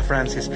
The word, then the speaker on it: FRNCS: Frances es importante, se escribe solo las consonantes, FRNCS. – Exactamente, sin la A y sin la E. Ahí está, Francis.